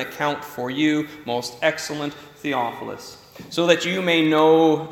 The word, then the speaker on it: Theophilus: And both Luke and Acts are addressed to Theophilus. That's how we were going to pronounce it, right? Theophilus? Right.